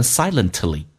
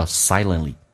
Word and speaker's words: silently: 我们一般会说成 silently，但是在美式发音当中 T 跟 L 组合 T 可以不发音，叫 silently。